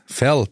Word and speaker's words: Verl: Verl, bitte. – Verl, sorry. – Es wird mit V ausgesprochen wie Vogel. Aha. Und nicht mit W wie Weltuntergang.